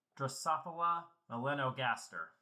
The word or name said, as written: Drosophila